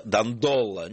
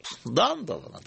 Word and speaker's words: Дандоло: Итак, самое главное для меня была новость в том, что правильное ударение, что всю жизнь я ставил неправильное ударение в этой фамилии. Я всегда читал, что самым знаменитым дожем Венеции является Энрико Дандоло. Нет, Дандоло. – Дандоло. Мои учителя тоже произносили Дандоло.